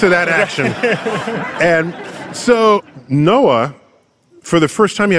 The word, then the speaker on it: NOAA: It's fun because to pronounce that is NOAA.